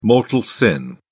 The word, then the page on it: mortal sin: British English